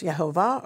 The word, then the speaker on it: Yehovah: First of all, we learn that God's sacred name is the letters Yud, He, Vav, He, meaning I am that I am, pronounced Yehovah, according to Bible scholar Nehemiah Gordon, and there is no other God but the God of Israel.